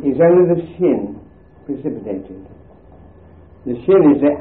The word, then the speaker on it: shin: And if you put it on the sinister side, it is pronounced shin. And if you put it on the dexter side, it is pronounced sin.